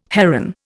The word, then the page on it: heron: Indian